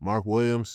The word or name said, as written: Mark Williams